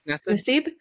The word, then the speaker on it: Nassib: Nassib.